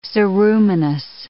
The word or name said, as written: ceruminous